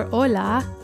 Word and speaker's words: hola: In Spanish, that word is spelled H-O-L-A, but the word for ocean wave is O-L-A, even though they sound the same.